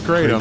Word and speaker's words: Kratom: Yeah, despite the pronunciation on our newscast, I believe it's known as Kratom.